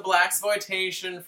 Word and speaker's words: blaxploitation: Who is described as a blaxploitation Freddy Krueger.